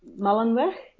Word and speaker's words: Mullenweg: Okay, his name was Matt Mullenweg, and I pronounce it the Dutch way because it's actually a Dutch name.